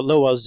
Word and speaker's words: Lwa: So let's go back to the Loas just for a minute, and I'm saying Loa, but it's spelled L-W-A.